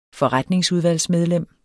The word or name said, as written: forretningsudvalgsmedlem